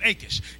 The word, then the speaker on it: Achish: Okay, that's how you pronounce it, Achish.